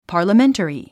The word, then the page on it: parliamentary: pὰː r ləmént ə ri; パァラメンタリィ